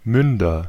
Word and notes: Münder: /ˈmʏndɐ/

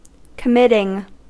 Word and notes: committing: American English

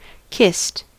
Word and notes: kissed: [kɪst]; American English

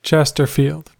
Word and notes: Chesterfield: American English

